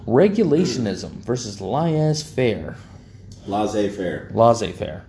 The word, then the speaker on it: laissez faire: But the man who I heard say it, Samuel Dish, is one of the smartest men when it comes to American government and economics I've ever known. He says it's laissez faire. As far as I'm concerned, that's how it's pronounced.